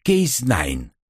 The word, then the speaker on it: Cas9: «Криспер что?» CRISPR-Cas9 произносится как Case9 или CRISPR-Cas9, но пишется как CAS9.